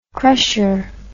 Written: crusher

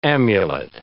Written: amulet

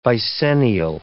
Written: vicennial

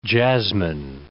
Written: jasmine

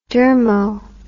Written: dermo

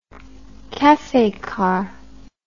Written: cafe car

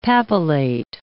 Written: papillate

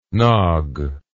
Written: nogg

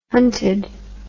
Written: hunted

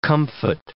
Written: comfit

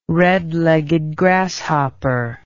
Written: red-legged grasshopper

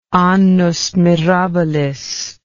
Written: annus mirabilis

